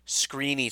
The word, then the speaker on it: Skrine: Screeny Skrine. I know it's screen, but it's spelled scrine, so whatever. It's weird.